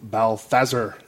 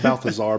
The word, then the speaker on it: Balthazar: And you find out basically that this uncle was enslaved to a demon cat named Balthazar, as they pronounce it. Not Balthazar, but Balthazar.